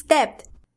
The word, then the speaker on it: debt: The word is debt. – Very good. Debt. D E B T. Again, there is a silent B.